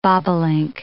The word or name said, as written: bobolink